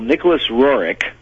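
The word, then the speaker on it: Nicholas Roerich: Well, Nicolas Rorick, that's apparently the way the name is pronounced.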